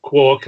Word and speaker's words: quark: I mean, he laid down that you pronounce it quark, and then I think he probably post-fitted it to the literature. I think he just liked the sound of the word quark, and then found a literary reference where it appeared as quark. But certainly quark is definitely the pronunciation.